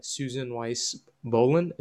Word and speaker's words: Weis-Bohlen: I'm here with my co-host Sam, and today we're talking to Susan Weis-Bohlen. Is that how you say your last name? – You got it. Perfect.